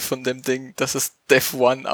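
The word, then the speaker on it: Devuan: Es wird Devuyan ausgesprochen, habe ich vorher gerade gelesen.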